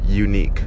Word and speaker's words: Uneek: And uh, you can follow me on all major platforms, mostly under the handle Robert Unique Courtney. And unique is spelled U N E E K.